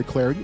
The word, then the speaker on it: Clardy: I'm indeed Troy Clarity. Follow me on Twitter at Troy Clarity. Last name is spelled C-L-A-R-D-Y.